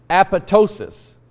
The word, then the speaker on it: apoptosis: And the word that you see up here, apoptosis, and it's pronounced apoptosis. The P is silent, the second P is silent, like in the word banana, the P is silent.